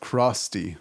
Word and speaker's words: Crosty: Today we are with George, the founder of Crosty. That's how you say it, right? – Yep.